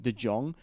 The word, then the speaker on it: De Jong: Olivia De Jong, who also, I mispronounced her name earlier. It's De Jong, not De Jong.